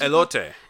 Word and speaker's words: elote: And didn't you get how do you say it, an elote? What is elote.